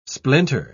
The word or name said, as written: splinter